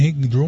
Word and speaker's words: hang drum: Esse é o hang drum. Eu falei hang drum, mas é hang drum. Eu estava falando errado até a pronúncia aqui desse instrumento.